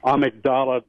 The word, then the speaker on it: amygdala: Wanted to ask Amanda if she believes there's a connection between authoritarianism and the amygdala part of the brain, or as it's sometimes pronounced, the amygdala.